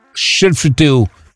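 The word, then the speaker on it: Skellefteå: Voilà comment prononcer. Shell. Shell. Feu. Théo.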